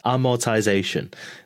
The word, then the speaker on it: amortization: Well, for anybody that wants to know the definition, according to Google, for amortize amortize I'm sorry, I'm butchering this. Amortization. The action or process of gradually writing off the initial cost of an asset.